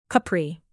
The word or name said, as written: capri